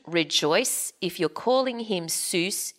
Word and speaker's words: Seuss: So what this book says is that he thought his name, well, he pronounced his name Seuss, rhyming with voice. – Ah. – And no one else said Seuss.